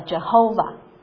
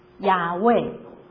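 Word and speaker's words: Yahweh: 但是后来呢，有时候有些人他们就把这个混淆在一起，把这个母音就加到 YHWH 里面，就变成了 Jehovah，也就是耶和华。但是 YHWH 如果真的要发音的话，最好可能是把它就是发读成雅味。所以神的名正确的来说是雅味，而雅味这个名字跟 I am who I am，我是那位我是，其实是一个双关语。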